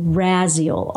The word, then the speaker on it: Raziel: And this is Archangel Raziel, R-A-Z-I-E-L. That's how I pronounce it.